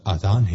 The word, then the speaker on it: آذان: آذان ہے یا اذان، بعض لوگ آذان کہتے ہیں، یہ غلط تلفظ ہے۔ آذان جمع ہے اذن کی، اور اذن کے معنی ہے کان۔ درست تلفظ آذان ہے۔